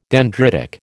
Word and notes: dendritic: American English

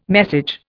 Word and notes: message: Indian

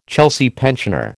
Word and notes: Chelsea pensioner: American English

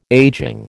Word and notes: ageing: American English

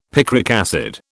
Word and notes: picric acid: British English

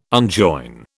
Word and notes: unjoin: British English

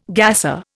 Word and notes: gasser: Indian

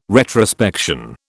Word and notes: retrospection: British English

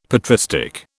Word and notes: patristic: British English

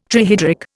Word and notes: trihydric: Indian

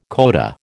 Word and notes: coda: American English

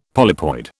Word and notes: polypoid: British English

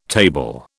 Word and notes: table: British English

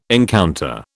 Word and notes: encounter: British English

